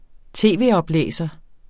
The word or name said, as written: tv-oplæser